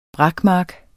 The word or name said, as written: brakmark